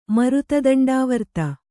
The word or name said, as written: ಮರುತದಂಡಾವರ್ತ